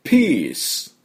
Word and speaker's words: peace: で次はですね、 Peace、これもやっていきたいと思います。 Peace のスペルは P-E-A-C-E なんですね。でこれローマ字読みしちゃうとですね、ペアセって読みたいところですけども、この PEA っていうのが出て、E って伸ばすことが多いですね。お茶なんかも T って言うじゃないですか。だから EA は E って申します。伸ばします。